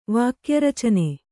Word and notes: ವಾಕ್ಯರಚನೆ: Kannada

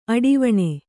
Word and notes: ಅಡಿವಣೆ: aḍivaṇe

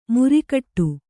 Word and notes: ಮುರಿಕಟ್ಟು: Kannada; muri kaṭṭu

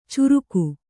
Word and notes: ಚುರುಕು: curuku

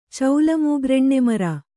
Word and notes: ಚೌಲಮೂಗ್ರಎಣ್ಣೆಮರ: caula mūgra eṇṇe mara

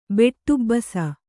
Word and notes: ಬೆಟ್ಟುಬ್ಬಸ: beṭṭubbasa

